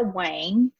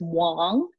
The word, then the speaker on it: Wang: My name is Clara Wang. In Chinese, you do pronounce it as Wang. But, you know, I've just gotten used to everyone just saying Clara Wang, you know, Vera Wang.